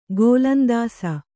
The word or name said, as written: ಗೋಲಂದಾಸ